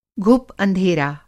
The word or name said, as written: घुप-अंधेरा